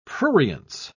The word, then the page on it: prurience: British English